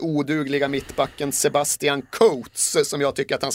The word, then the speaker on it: Coates: Men ska ändå uttalas Coates för att så säger de då tydligen Uruguay.